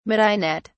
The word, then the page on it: midinette: British English